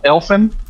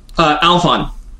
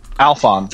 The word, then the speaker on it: Alphon: I think that I am, I'm sorry, is it pronounced Elfin? – Alphon. – Alphon. – Think like okay, Alphons, without the z at the end.